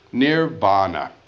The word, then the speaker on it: nirvana: Beyond, near, N-I-R, vana, V, long A, N with a dot under it, A. Nirvana, beyond winds.